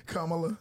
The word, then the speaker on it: Kamala: How you say her name? – Kamala. – There you go.